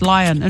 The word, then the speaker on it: Lyon: Lyon in France.